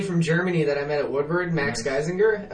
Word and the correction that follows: Geisinger: I like how you said his name. – Yeah, I think that's how you pronounce it. – Try again. – It's Geisinger.